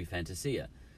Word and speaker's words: Fantasia: We're pronouncing it wrong. We're anglicising it. He's Italian. It should be Fantasia.